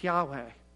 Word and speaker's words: Yahweh: And each time, it's using the proper name for God, the Hebrew four letters that we would pronounce Yahweh.